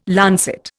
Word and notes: lancet: Indian

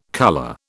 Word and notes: colour: British English

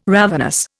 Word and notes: ravenous: Indian

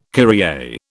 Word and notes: Kyrie: British English

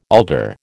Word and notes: alder: American English

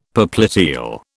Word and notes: popliteal: British English

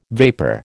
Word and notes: vapor: American English

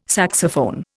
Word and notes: saxophone: Indian

